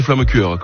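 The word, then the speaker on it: flammekueche: On peut dire, ouais, ça dépend de la prononciation. – D'accord. – Chez moi, Maestratheim, c'est flammecure, comme on flammecourt.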